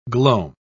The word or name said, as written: gloam